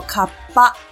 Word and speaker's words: 河童: 日文呢念作卡巴，是从河川的卡哇加上儿童的哇拉贝这两个字呢念在一起，最后变成了卡巴这个词。